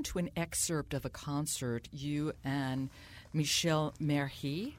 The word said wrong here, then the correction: Merhej: Am I saying his name correctly? – Yeah, yeah, Merhej.